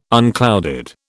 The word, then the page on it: unclouded: British English